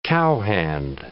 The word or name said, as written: cowhand